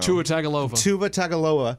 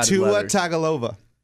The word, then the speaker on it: Tagovailoa: Obviously, this is the next big thing for Alabama, this kid, Jamamamalua. Like, but... – Get the pronunciation down what is it? Next week. I don't know. – Tua Tagovailoa. No, Tua. – Tua Tagovailoa.